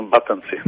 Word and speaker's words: Батънци: Батънци е ударението или не? – Точно Батънци.